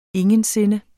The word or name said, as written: ingen